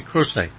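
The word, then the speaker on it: Croce: It's pronounced, by the way, Crochet. Crochet, mm-hmm.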